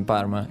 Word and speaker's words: Parma: It's that you shouldn't pronounce the R in Parma.